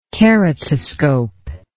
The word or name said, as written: keratoscope